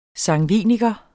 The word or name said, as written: sangviniker